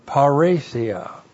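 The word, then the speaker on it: paresia (παρρησία): Looks like that in the Greek, P-A-R-R, long E-S-I-A, paresia.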